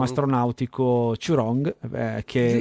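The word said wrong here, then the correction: Jurong: Giurong, Giurong.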